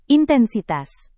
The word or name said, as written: intensitas